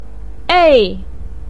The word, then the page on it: ei4: pinyin